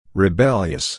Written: rebellious

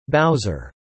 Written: Bowser

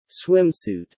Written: swimsuit

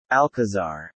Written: Alcazar

